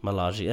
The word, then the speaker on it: Milaje: To focus on T'Challa's bodyguards, and I hope I remember how to pronounce this, the Dora Milaje? – Milaje, that sounds right.